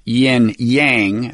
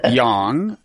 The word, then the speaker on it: yang: Now, Americans are probably accustomed to pronouncing that pair of syllables, yin yang. That's the way it's known here. But I learned from you that the correct pronunciation is yang. – Yes. – Y-A-N-G.